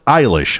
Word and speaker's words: Eilish: It's that pop star Billie Eilish. – It's pronounced Eilish, huh? – Billie Eilish, yeah.